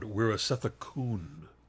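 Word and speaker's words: Weerasethakul: You guys do a fine pronunciation of the name, except his last name should end in an N sound, Wirasathakun. – Pichatpong Wirasathakun. Okay. – This is because of a rule in Thai that when an L comes at the end of the word, it is pronounced as an N.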